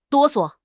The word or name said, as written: 哆嗦